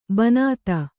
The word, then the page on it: ಬಿಣ್ಣಿತು: kannada